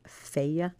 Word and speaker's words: Fage: And the first yogurt that I really fell in love with at the store was that stuff called Feia. It's spelled phage, F-A-G-E, but it's pronounced Feia.